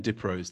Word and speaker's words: Diprose: And Lee is spelled L-E-I-G-H. That's correct? – That's correct. And Diprose, D-I-P-R-O-S-E.